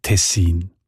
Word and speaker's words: Tessin: Und auch im Hochdeutschen sieht man Tessin. Die Variante mit der Betonung auf der ersten Silbe und mit kurzem I, also Tessin, ist seltener. Im